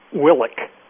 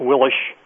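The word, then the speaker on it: Willich: Willick, as if with a K. – It's spelled with an H, but it has the K sound. Some people call him August Willish, but I think the most popular pronunciation is August Willick.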